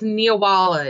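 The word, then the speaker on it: Neewollah: It's Nawala. It's Halloween spelled backwards.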